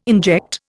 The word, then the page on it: inject: Indian